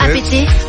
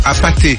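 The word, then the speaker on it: APT.: C'est pas apété. – Mais non, c'est apaté. – Apaté.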